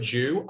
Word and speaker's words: Dew: That Jew with a D. In Australia, we kind of don't make that like Jew or Jew. Like it's kind of pronounced the same. It's D-E-W.